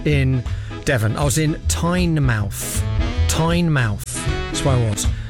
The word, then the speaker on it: Tynemouth: She says, "Hi, writing to you from Tynemouth." I'm saying it wrong deliberately. In Devon, as I shout at the screen, it's pronounced Tynemouth.